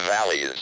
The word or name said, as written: valleys